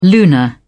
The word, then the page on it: lunar: British English